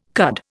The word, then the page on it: cud: Indian